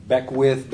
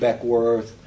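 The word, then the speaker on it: Beckwourth: Beckwith, Beckworth. His name is pronounced in a variety of ways.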